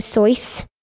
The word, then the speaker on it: Seuss: Did you know that Dr. Seuss pronounced his name Sois? – I love Dr. Sois.